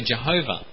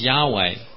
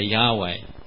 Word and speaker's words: Yahweh: Actually, it probably isn't the correct pronunciation, and I'll mention in a minute. But the other way we pronounce it is Yahweh. You may have heard that sometimes. People say Yahweh, okay? Y-A-H-W-E-H.